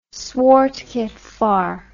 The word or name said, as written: swart gevaar